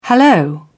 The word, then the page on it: hallo: British English